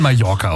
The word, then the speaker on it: Mallorca: Wie spricht man Mallorca aus, richtig? – Ja, gut.